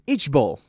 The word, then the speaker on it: IJBOL: But if you want to be more in trend, the new term to replace lmao is ichbol. Sounds very, very ugly, okay? It is spelled as I-J-B-O-L, which means I just burst out laughing. Ichbol.